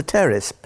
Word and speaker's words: Pteris: Well, something else, there was a terrace, and for everybody listening, I have to tell you that's P-T-E-R-I-S. It's a silent P, a terrace fern called Wallichiana.